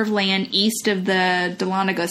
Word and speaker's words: Dahlonega: And then the last one is the Smith House in Dahlonega. Am I saying that right? – Yeah. – Dahlonega, Georgia.